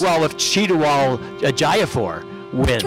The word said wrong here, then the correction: Chiwetel Ejiofor: To die for a proper pronunciation. It's Chiwetel Ejiofor.